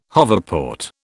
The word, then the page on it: hoverport: British English